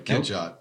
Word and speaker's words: Catchot: Ketchot.